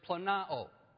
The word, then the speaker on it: planao: It's a word, in the original language, the word is planao. Planao. Phonetically, it'd be spelled P-L-A-N-A-O.